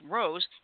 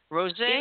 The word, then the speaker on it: rosé: Of those who reported drinking on the holiday, 47% drink red wine, 39% drink white wine, 20% drink sparkling wine, 17% drink rose, and 7% drink orange wine. I didn't know they had orange wine. – Surely that's pronounced rosé. – Rosé? – Even though it's spelled rose, it's pronounced rosé.